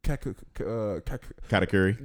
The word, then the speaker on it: Katakuri: I'm gonna give you Katakuri.